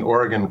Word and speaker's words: Oregon: Oregon in 2013. Thank you so much, Archbishop Sample, for being with us. – Well, thank you, Cy, and thank you for pronouncing Oregon correctly.